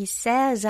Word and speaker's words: says: 이 says 할 때 생각보다 입을 양옆으로 좀 길게 약간 찢어준다 이런 느낌으로 좀 더 많이 벌려주셔야 해요.